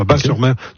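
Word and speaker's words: Batz-sur-Mer: Et surtout, la crêperie s'appelle le Dervin à Bas-sur-Mer, qui font les meilleures crêpes on prononce bas, le T ne se prononce pas, Bas-sur-Mer.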